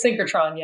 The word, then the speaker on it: synchrotron: I think I'm saying that right. – The synchrotron, yes.